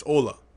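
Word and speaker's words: Hola: Pony Pledge, don't pronounce the H in Hala. Ah, Ola, right? It's Ola.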